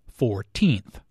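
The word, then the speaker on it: fourteenth: The stress is on the second syllable, 14th.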